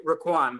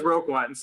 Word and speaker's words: Roquan: Yeah, and even so, it's almost like if the Bears go into the playoffs without Raquan, his mom pronounces his name Raquan, by the way. So I try to, you know. – That makes sense. Thank you. – He still says Roquan, so I think it's fine to say Roquan, but, you know, I feel like his mom named him.